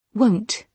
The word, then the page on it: won't: British English